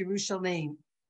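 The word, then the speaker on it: Yerushalayim (ירושלים): We pronounce it Yerushalayim even though it's Yerushalayim.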